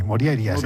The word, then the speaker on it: Morjaria: Bueno, la J normalmente se pronuncia como I, Morjaria.